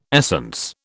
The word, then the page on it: essence: British English